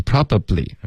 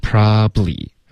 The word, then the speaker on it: probably: OK, probably, 不要发成 probably, OK, 用它的这个缩写的发音 probably, OK, 两个音节就够了。